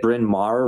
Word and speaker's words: Bryn Mawr: Now, did I get Bryn Mawr right? – You did, actually. – Okay, nice. – Yeah, it's not really intuitive how you pronounce it, but yes. – No, two words, one vowel.